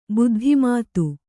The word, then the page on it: ಬೌದ್ಧಮತ: bauddha mata